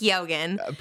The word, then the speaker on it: Keoghan: I know his name now. It's Keoghan. – Period. – That's how you say it.